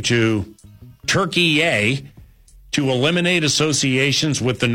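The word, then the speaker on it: Türkiye: Turkey formally changed its name to Turkey A. They say Turkey A. There are other pronunciations that go Turkey, yeah, but it's not turkey. It's not like what you eat at Thanksgiving. It's not like three strikes in a row at a bowling alley.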